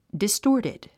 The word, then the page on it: distorted: distɔ́ːrtid; ディストーティド